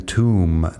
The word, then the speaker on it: tomb: Así que climb con la B al final y también tomb de tumba con la B al final, que no suena.